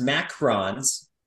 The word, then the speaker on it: macaron: And there's one I think I always pronounce it wrong, but I'm going to take the one spelled M-A-C-A-R-O-N, not the O-O-N. It's pronounced macarons, and they are little expensive, almost like sandwich-like cookies that are different flavors.